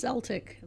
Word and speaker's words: Celtic: So we're talking about Celtic music, which apparently there's can be pronounced Celtic. – Celtic, you know, like, or the Celtic. Celts? – Yeah. – Yep. – Yeah, so either way, you're doing it right.